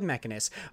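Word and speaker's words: Mechinus: Now over on Twinfinite, where it's reported that puzzle platformer Mechanus, it's M-E-C-H-I-N-U-S. How would you say that? – Mechanus? – Okay, let's go with Mechanus.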